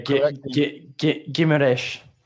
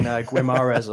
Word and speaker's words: Guimarães: Oh, wait, Go on. – Yeah, Guimarães. – Guimarães.